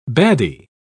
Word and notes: baddie: British English

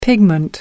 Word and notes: pigment: American English

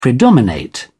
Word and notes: predominate: British English

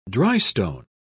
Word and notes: drystone: British English

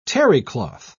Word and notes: terrycloth: British English

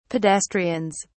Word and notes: pedestrians: British English

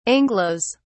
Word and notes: anglos: British English

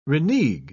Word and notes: renegue: British English

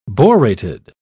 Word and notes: borated: British English

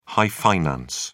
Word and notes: high finance: British English

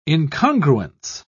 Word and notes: incongruence: British English

